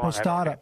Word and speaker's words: Posada: You just you um, said it wrong. Posada. Posada. Posada. Right.